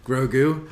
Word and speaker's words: Grogu: So anyway, that's Goku's backstory. – Grogu. – Grogu. I cannot get the name right.